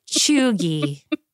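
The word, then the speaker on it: cheugy: At GDLee9270 asked, what do you think about the term chuggy? – Okay, some basic background.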